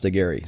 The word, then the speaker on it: Aguirre: It's Forrest, and my last name is spelled Aguirre, although it's pronounced Aguirre, so Forrest Aguirre.